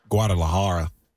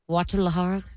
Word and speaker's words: Guadalajara: You say, you don't know how to say Guadalajara. – Guadalajara? – Yeah, we had that on tape.